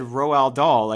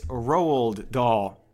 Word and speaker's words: Roald Dahl: So his name is spelled R-O-A-L-D. So I said Roald Dahl. Like, that's the way it looks, which, okay, maybe stupid. Leave me alone. But so it's pronounced, I looked it up because they were like, you fucking moron. I looked it up. It's Roald Dahl, which is almost the exact same thing I just said.